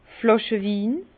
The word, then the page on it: Flàschewin: Alsatian; Bas-Rhin; Herrlisheim